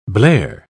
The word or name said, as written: blare